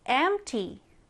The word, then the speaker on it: empty: empty. empty. 자, E-M-P-T-Y고요. P는 정말 약하게 발음을 해요. 그래서 empty가 되겠고요. 자, 오른쪽에 같이 한번 써볼게요. 여러분, empty 스펠링 외워서 써봅시다. empty, E-M-P-T-Y.